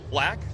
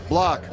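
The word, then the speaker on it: Blach: Clayton Kershaw versus Ty Black on Black? Block? – Block. – Block. Spelled Blatch, pronounced Block.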